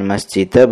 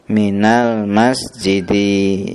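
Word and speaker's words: min al-masjidi: Minal masjidi, jadi kita bacanya apa? Masjidi ya kan, bukan masjidah, bukan masjidu. Kenapa? Karena ada min huruf jar, jadi kita kasrah sesudahnya ya kan. Kita baca apa? Minal masjidi ya, paham ya.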